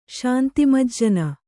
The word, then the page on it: ಶಾಂತಿಮಜ್ಜನ: kannada